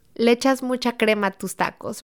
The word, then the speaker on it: Le echas mucha crema a tus tacos: Now say it correctly for me since I mangled it. – Le echas mucha crema a tus tacos.